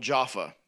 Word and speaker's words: Jaffa: In Hebrew, it would be called Jaffa. So if you would read the Old Testament, you might see it spelled as J-A-F-F-A, Jaffa. In the Greek, it's written and pronounced Joppa.